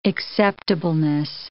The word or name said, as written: acceptableness